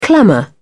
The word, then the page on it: clamour: British English